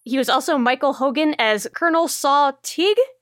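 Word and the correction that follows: Tigh: in Battlestar Galactica. Ty. Sorry if I pronounced that wrong. Oh, Colonel Saul Ty in Battlestar Galactica.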